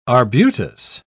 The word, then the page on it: arbutus: British English